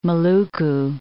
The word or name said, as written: Maluku